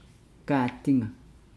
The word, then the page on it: gaadinga: Nukuoro